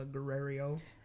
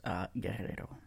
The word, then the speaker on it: Guerrero: Paola Guerrero? – It's actually pronounced Guerrero.